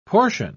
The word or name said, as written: portion